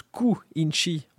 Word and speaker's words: Quincy: Quincy se prononce Ku-inchi en japonais, mhm.